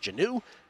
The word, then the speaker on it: Jonnu: 2 plays later, Ritter to Jonnu Smith, who I'm told is now pronounced Janu.